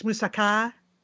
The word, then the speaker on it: moussaka: Is that correct? It's moussaka? – Yes. I mean, I wouldn't overthink that. But yeah, that's what they would say in Greece.